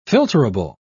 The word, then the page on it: filterable: British English